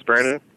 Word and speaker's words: Brandon: My name is Brandon. – Okay. – Can you spell that? – B-R-A-N-D-O-N.